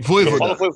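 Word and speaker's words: Vojvoda: Mas, mas o Vojvod tá, ou sei lá, como a PVC depois me ajuda na pronúncia aí. – Voivoda. – Voivoda. Voivoda.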